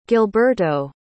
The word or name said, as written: gilberto